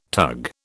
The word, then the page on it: tug: British English